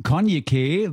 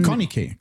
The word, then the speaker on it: Konyikeh: Konyke würde ich sie aussprechen. – Konyke. – Konya, da ist ja so ein Y, also K-O-N-Y-I. K-E-H, so wird es geschrieben, für die Leute, die es nochmal ganz genau wissen wollen. Wie es ausgesprochen wird, ist vielleicht auch ein bisschen, liegt im Auge des Betrachters, der Betrachterin.